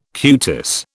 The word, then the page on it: cutis: British English